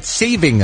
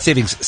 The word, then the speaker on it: saving: It's the end of daylight saving time is what it is. Not savings. Saving is how you say that.